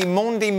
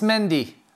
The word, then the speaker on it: Mendy: Hey, and I was interested in the Mondi Mendy pronunciation. Frank, what are we going for? – It's Mendy.